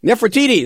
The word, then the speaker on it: Nefertiti: Nefertiti.